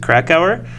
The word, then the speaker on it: Krakauer: Into the Wild by John, I'll pronounce it Krakauer. – Oh. – Yeah, John Krakauer, K-R-A-K-A-U-E-R.